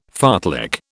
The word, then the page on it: fartlek: British English